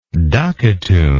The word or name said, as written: ducatoon